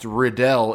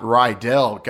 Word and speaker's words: Riddell: Besides being shocked that it's pronounced Riddell and not Rydell, guys, this news about this new helmet technology that they obviously plan to announce Super Bowl week, that you saw this.